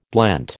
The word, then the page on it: blent: American English